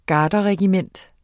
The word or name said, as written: garderregiment